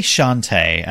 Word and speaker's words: Shantae: I say Shantae, and I think that's how they say it in the cutscene, so that's how I'm going to go with it. – Shantae. It sounds a bit like shanty, so...